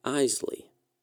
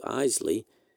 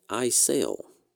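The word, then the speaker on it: Eisele: Administrator Jim Webb introduced the crew to President Johnson, and when he came to Isley, he stumbled over the astronaut's surname. He pronounced it as Isel.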